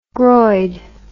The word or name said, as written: groid